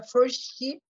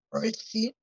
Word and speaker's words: 1STI: ela tem uma empresa chamada Forsti, que se escreve 1STI, Forsti, ela me convida né,